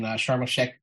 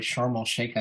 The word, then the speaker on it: Sharm el-Sheikh: And, you know, this comes out of COP27, which took place in Sharm el-Sheikh, Egypt. Didn't say that right. Sharm el-Sheikh, I think.